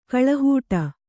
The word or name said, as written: ಗಾಳಿಹಿಡಿ